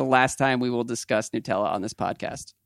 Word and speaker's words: Nutella: Once and for all, he says, it is made by an Italian company and the correct pronunciation is Nutella with the U.